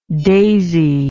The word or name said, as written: daisied